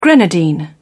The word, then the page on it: grenadine: British English